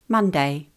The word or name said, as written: Monday